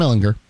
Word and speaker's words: Ehlinger: So we know Sam Ehlinger, right? Hard G. Sam Ehlinger.